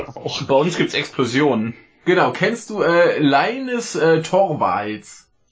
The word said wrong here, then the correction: Linus: Äh, ja, ich glaube, der spricht man Linus.